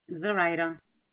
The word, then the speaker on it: Zoraida: This is Zoraida speaking. How may I help you today? – I'm sorry, what was the name? – Zoraida. – Can you spell it for me? – Yeah, Z-O-R-A-I-D-A. – Z-O-R-I-D? – A.